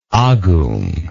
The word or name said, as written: agung